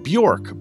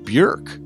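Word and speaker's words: Björk: The name B-J-O umlaut R-K is pronounced Bjork by most folks in the U.S., but she pronounces it Bjork. It's her name, so she should choose how it's pronounced.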